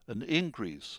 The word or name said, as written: increase